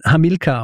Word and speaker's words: Hamilcar: und im Fall von Hamilcar ist es eben tatsächlich so, dass man ihn Hamilcar aussprechen muss, weil es eine schwere Silbe ist, ja, im Vergleich zu Hannibal.